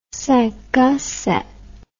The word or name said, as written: cinq a sept